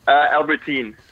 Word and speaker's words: Albertyn: Albertine.